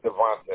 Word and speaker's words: Davonta: And then your first name, D-O-N-T-E? – Oh, actually, my name is Devonte. I just go by Dante. – Okay, how do you spell that? – D-A-V-O-N-T-A.